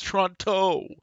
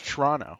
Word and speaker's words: Toronto: But it's like, oh yeah, it has a second T in it, right? It's Toronto. But there's the T is silent. It's Toronto.